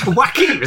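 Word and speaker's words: Joaquin: Joachim, is that how you pronounce it? – That's how I pronounce it. – Yeah, that's the correct way.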